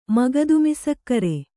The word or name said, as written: ಮಗದುಮಸಕ್ಕರೆ